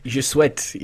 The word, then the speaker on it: Je Souhaite: I'm going to mispronounce this, Je Souhaite. – Je Souhaite, yes. I think you got that right there, yeah.